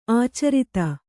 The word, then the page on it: ಆಚಾರತೆ: ācārate